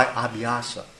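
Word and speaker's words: abhyāsa: By abhyasa. Do you know this word, abhyasa? A-B-H-Y, long A-S-A, Abhyasa, or Abhyasa Yoga.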